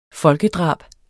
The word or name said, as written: folkedrab